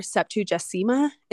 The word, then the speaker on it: Septuagesima: Septuagesima is how you say it. It's spelled S E P T U A G E S I M A.